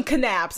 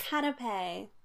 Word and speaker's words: canapé: There is an accent. An accent grave. – Canapé.